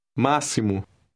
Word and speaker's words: máximo: In this word, the X is pronounced like the S in the English word see. Listen and repeat word 8. – Máximo.